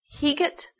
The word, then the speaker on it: Heget: And I would say her name is Heget, H-E-G-E-T, Heget.